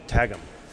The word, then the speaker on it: Qwghlm: So if you translate that, when it's spoken that way, the local English people would say tagum.